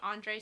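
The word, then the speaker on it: Andrzej: I know I'm not pronouncing it right to everyone who knows, but I think it's like Andre Sapkowski. That's the would be the Americanized version.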